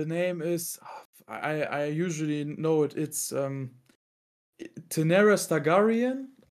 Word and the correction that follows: Daenerys Targaryen: I think I've so, said it wrong. it's something with Stargarian, I know. I'm, I, to be honest, I'm not so into Game of Thrones. I, I never watched it really. But I think her name is Daenerys Ta. Yeah, it's Daenerys Targaryen.